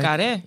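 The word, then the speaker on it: Carré: The Little Drummer Girl by John Legar. Lecar, did I pronounce that right? Lecar.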